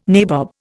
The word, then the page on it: nabob: Indian